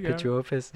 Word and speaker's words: Pituophis: Yeah, I'm I'm all about Pituophis now. I think I pronounced that right. – Mm-hmm. – Did I get that one right? Pituophis.